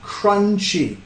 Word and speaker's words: crunchy: Acá tenemos el ch, ch, ch. Empieza con la lengua arriba, después soltándolo. Ch. Ch, ch, crunchy. Ok.